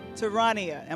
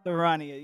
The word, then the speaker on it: Taroniah: Taroniah, am I saying that right? – Oh, yes. – Taroniah, yes.